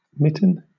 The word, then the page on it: mitten: British English